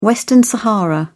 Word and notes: western sahara: British English

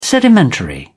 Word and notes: sedimentary: British English

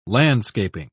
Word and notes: landscaping: British English

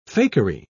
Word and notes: fakery: British English

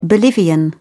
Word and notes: bolivian: British English

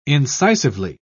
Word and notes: incisively: British English

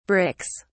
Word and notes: bricks: British English

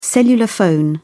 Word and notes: cellular phone: American English

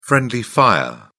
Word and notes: friendly fire: British English

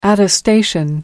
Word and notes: attestation: American English

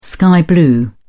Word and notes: sky blue: British English

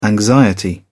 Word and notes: anxiety: British English

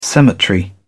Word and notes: cemetery: British English

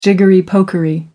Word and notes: jiggery-pokery: American English

